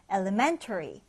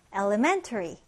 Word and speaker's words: elementary: 여기 발음 기호에 있는 강세 표시 주의해서 보세요. 여기 보시면 이렇게 왼쪽 위로 올라가 있는 건 제2강세이고요. 이렇게 오른쪽 위로 올라가 있는 게 1강세입니다. 그래서 elementary, elementary 이렇게 읽어줄 수 있죠.